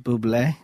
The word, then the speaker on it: Bublé: Bublé? – Bublé.